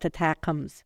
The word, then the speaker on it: ATACMS: In a video posted to social media, Ukraine's President Volodymyr Zelensky confirmed the military had used an Army tactical missile system, known by its acronym, which is pronounced ATACMS.